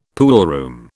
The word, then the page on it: poolroom: British English